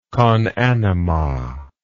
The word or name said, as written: conanima